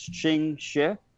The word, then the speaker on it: Ching Shih: So the female pirate queen, the Asian female pirate queen, it was, I'm probably going to butcher this, but it's Ching Shih. – She? – Ching Shih. – Yeah. I think Shih is she. Or she. She.